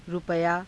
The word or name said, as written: rupayaa